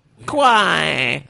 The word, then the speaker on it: Kawhi: Kawhi! That's the name of the shoe. You have to say it like that.